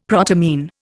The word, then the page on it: protamine: Indian